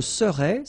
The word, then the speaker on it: serais: Je serais, same thing here, final S not pronounced.